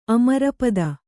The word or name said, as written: ಅಮರಪತಿ